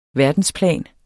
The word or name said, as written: verdensplan